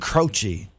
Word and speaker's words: Croce: Jim Croce, Jim Kake, Jim Croce is how you say it.